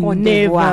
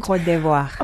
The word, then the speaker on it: Côte d'Ivoire: So apart from Ethiopia and Ivory Coast, Côte d'Ivoire, can Côte d'Ivoire. Never pronounce it right. – Côte d'Ivoire.